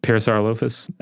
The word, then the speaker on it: Parasaurolophus: How do you say that one? – Parasaurolophus. – Okay. – That's the way my son says it.